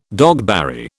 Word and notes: dogberry: British English

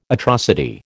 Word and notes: atrocity: American English

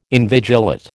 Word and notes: invigilate: American English

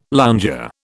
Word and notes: lounger: British English

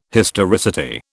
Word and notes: historicity: British English

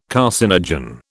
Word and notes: carcinogen: British English

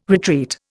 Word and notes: retreat: Indian

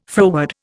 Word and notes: froward: Indian

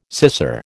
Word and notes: scissor: American English

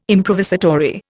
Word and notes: improvisatory: Indian

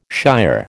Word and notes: shier: American English

